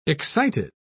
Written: excited

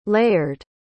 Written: layered

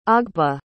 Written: ogbe

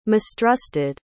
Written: mistrusted

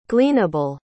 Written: gleanable

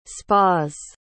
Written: spas

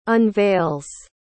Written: unveils

